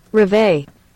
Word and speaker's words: Reveille: Huh, but the pronunciation that I looked up says just pronounce it like this. – Reveille.